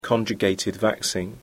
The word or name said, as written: conjugated vaccine